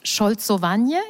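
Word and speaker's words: szolcowanie: Es gibt im Polnischen ein neues, relativ neues polnisches Wort, Scholzowanie. Ich hoffe, ich habe das richtig ausgesprochen.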